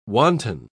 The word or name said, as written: wanton